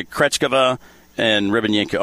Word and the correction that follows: Rybakina: Rybakina? – Rybakina.